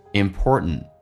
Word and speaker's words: important: 那么分享是非常重要的，我们来看一下，首先其中有一个实义词汇叫 important， T 跟 N 在一起的时候，或者 N 跟 T 在一起的时候，那么 T 都可以不发音，那这边通常口语化当中不会说成 important，会变成 important， t 不发音，第一个 t 最后一个 t 也不发音，最后一个 t 不发音属于叫做略音，因为后面是一个单词是以辅音开头，所以这句话就变成，这个单词就变成 important。